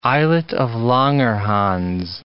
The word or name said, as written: islet of Langerhans